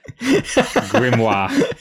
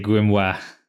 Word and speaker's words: Grimoire: For people that are hunting Grimer score, I'm sorry, hunting what? – I don't know why I pronounced it like that. Grimoire. I'm just going to say Grimoire.